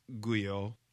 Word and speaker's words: Guyo: Guyo. My last name is spelled G-U-Y-O, or you can search Berean Sovereign, just Berean Sovereign, and we'll see our messages there also.